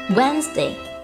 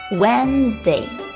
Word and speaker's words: Wednesday: Wednesday, Wednesday，星期三。第一个字母 d 不发音，其余单词发音。正确的读音是 Wednesday，注意中间摩擦音 z 的发音。